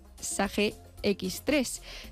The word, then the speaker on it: Sage X3: La persona seleccionada desempeñará funciones propias de controller financiero, siendo imprescindible el manejo del software de gestión SAGE X3, si se dice así, no lo sé. – Sí, Buscan un perfil proactivo analítico. – Te confirmo, está bien dicho.